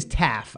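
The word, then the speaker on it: Taf: And his name is Taff. I believe that's how you pronounce his name, Chris. It's T-A-F.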